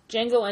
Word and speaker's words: Django: Django Unchained, the D is silent.